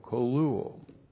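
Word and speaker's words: koluo (κωλύω): K long O, L U long O, koluo.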